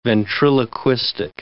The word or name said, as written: ventriloquistic